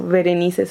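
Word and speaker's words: Verenice: Y en Facebook estoy como Berenice Solís. Berenice con V así se escribe, no se equivocaron, no me equivoqué.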